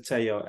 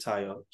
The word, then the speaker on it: Tayo: Most, I think 80% of people pronounce it Tayo, and that's also fine by me. I'm not that fussy. – Okay, awesome. – I think even within my family, I've got both Tayo and Tayo.